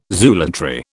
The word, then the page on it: zoolatry: British English